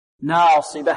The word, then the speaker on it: ناصبة: وكذلك ننبه على الألف هنا، لا نجزي الألف، نأتي في أولها مرقق، ناصية، ناصية، ناصية، ثم الجزء الآخر نفخمه لأجل الصاد. ناصبة، عاملة ناصبة، ناصبة.